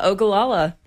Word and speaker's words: Ogallala: Malachi came in, and we're going to be playing a song for you up next, Ogallala. – Ooh, oh, how do you say it? – Yeah, Ogallala.